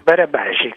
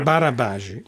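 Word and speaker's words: Barabási: L'autore del libro, noi abbiamo continuato a dire Albert Laszlo Barabasi, com'è che si pronuncia esattamente? – Barabasi, così come la pronuncia. – Barabasi, va bene.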